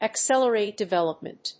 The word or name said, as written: accelerate development